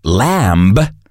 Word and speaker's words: lamb: Lamb is otra de las palabras que tiene la B muda. No se pronuncia lamb, sino lamb.